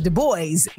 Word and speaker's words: Du Bois: And I was like, you know, yes, in the French pronunciation is that, but like people know him as Du Bois, you know?